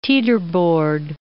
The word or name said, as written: teeterboard